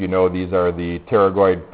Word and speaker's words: pterygoid: And since you know the name of this process, the pterygoid process, we don't pronounce the P, you also know the names of those muscles, more or less.